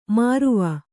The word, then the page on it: ಮರುವ: maruva